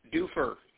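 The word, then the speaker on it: Dufur: And yes, it's Duffer. Don't try and pronounce it any other way. It's Duffer down here.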